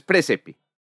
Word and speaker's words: praesepe: No es un diptongo, entonces hay que decirlo, es presepe. – Presepe. – Presepe, o sea, presepe. AE no hay que pronunciarlo por separado, sino presepe. – Sí, la AE suena como E.